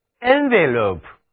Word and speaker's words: envelope: 再来看如果在上面那个动词 envelop 后加一个名词后缀字母 e，读音可就变了，读作 envelope， envelope。